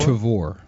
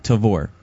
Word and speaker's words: Tavor: Tavor. – Tavor? – Yeah. Why do you say it that way? – There's no emphasis on the first syllable? – Because our brothers from the homeland say Tavor.